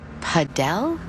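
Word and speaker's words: Padel: Wait, is that Paddle? – No, that's paddle. Rhymes with saddle.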